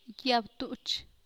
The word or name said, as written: Quialana